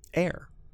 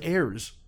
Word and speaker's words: Ayre: What is it? – Air. A-Y-R-E. – Oh, I thought it was Ayers.